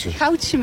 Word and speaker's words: Goudse: En zitten dan bij de HEMA echt de Goudaarse mensen, zeg ik dat goed? – Oh ja, Goudse mensen.